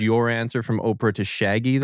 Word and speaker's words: Shaggy: You're saying it wrong, Brayden. It's Shaggy.